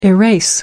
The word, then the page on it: erase: American English